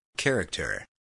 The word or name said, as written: character